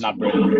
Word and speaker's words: Gnabry: Gnabry.